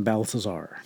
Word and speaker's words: Balthazar: I'm going to butcher this. Melchior, Caspar, and Balthazar. – Actually, you nailed it.